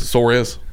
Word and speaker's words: Suarez: Pronounce it like Dale Jr., please. Suarez.